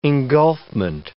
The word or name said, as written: engulfment